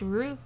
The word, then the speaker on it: route: That word is pronounced root.